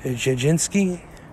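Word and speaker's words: Jędrzejczyk: Zhang Weili is going to be taking on Joanna, and we're going to slow for a second, and we're going to try the proper pronunciation, and it is Jajinski.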